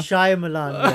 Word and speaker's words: Shyamalan: Shyamalan, yeah.